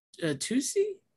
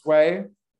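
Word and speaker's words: Tsui: So is it Tusi? – It's Sway. – Oh, Sway. Okay. – At least to my bad Chinese pronunciation.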